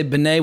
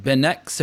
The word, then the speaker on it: Beineix: The film is directed by Jean Jacques I'm going to say Benet, which is not it, but it's like Benex, or B E I N E I X.